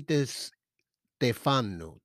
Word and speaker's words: De Stefano: And it's written by Anthony De Santefano. Anthony De Stefano. De Stefano. Okay, I said it wrong.